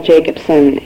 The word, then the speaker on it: Jacobson: My name is Janelle Jacobson, and that's spelled J-A-C-O-B-S-O-N, and I'm here speaking as a counselor in private practice in Moorhead.